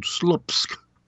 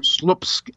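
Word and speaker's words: Słupsk: He's a mayor of a city of 100,000 called Slupsk. – Okay. – I think I pronounced that right. – All right. – Slupsk, S-L-U-P-S-K.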